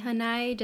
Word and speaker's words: hanai: I think it might be hanai just based on the pronunciation of lanai.